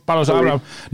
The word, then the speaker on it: Paulos Abraham: Hoe spreek je het zo uit? – Ja, Paulus Abraham.